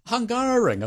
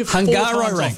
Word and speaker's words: Hungaroring: Nailed it. How do you say it? – Hungaroring. I've told you Hungaroring. Hungaroring.